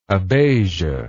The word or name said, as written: abasia